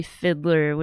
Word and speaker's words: Fideler: So I know if you just type in her name, Natalie Fidler, which is spelled F-I-D-E-L-E-R. Pronounced like Fiddler, but spelled different.